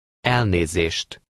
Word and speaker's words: Elnézést: Elnézést. Elnézést. – Note that in Hungarian, the stress is always on the first syllable of every word.